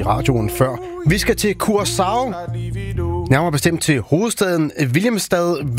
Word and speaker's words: Curaçao: Ja, hvis du må tage det helt korrekt, så siger det, hedder Curaçao. Det der C med den lille krølle under, det får sådan en sjav-lyd, men langt de fleste, de udtaler det blot som et SC, så Curaçao.